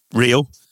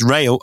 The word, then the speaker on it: Rio: A dream debut for Rio, but his name is pronounced Rayo, I'm told by my Japanese friends.